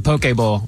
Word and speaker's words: poke bowl: I think I would say, because I've been corrected a bunch and I still may be wrong, like a poke bowl? I don't know if that's right. How do you say P-O-K-E? – Yeah, it's a poke bowl.